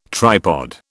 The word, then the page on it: tripod: British English